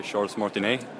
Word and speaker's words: Charles Martinet: Jo, ifall jag har förstått det rätt så är det väl den kön som leder till Charles Martinet. – Det är så man uttalar det, ja.